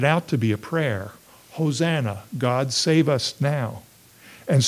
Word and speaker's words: Hosanna: And this word, Hosanna, or Hoshana, as it sometimes is pronounced in Hebrew, was prominent in these psalms.